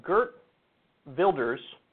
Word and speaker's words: Geert Wilders: He explains how Geert Wilders, who, by the way, his name is spelled G-E-E-R-T, but I think it's Geert, and Wilders is how I hear people pronounce it, but it's spelled W-I-L-D-E-R-S.